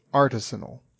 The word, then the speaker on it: artisanal: but a dictionary will tell you it's artisanal. – Right. – With the emphasis on R and the first syllable.